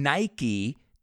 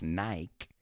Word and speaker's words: Nike: So this is not like something Nike, que de hecho no lo pronunciamos Nike, we say Nike.